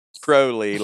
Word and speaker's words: Crowley: He tweeted, not that it matters, but it's Crowley like the bird.